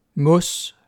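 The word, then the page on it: mos: Danish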